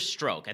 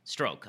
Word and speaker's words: Strzok: An actual bombshell drops, and that is that there is an agent named Peter Stroke. I think that's how it's pronounced. S T R Z O K. Peter Strzok, Strzok Stroke.